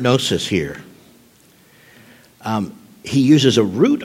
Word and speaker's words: gnosis: Usually the word for knowledge is gnosis, G-N-O-S-I-S, the G is silent, unless you, like I did, learn Greek in the South, and then it's gnosis, because y'all got to put all them letters in there.